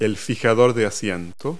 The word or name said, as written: seat stay